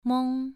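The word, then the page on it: meng: tone 2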